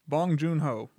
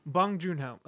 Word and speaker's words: Bong Joon-ho: Yeah, I mean, like, if a movie's great, and it's great, I mean, plus Bong, I'm going to keep saying his name wrong, Bong Ho. – The director? – Yeah. – Bong Joon-ho. – Bong Joon-ho, okay.